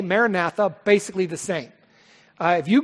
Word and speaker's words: Maranatha: Maranatha.